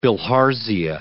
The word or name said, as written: bilharzia